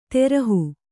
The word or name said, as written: ತೆರಹು